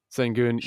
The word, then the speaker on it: Sengun: Sengun.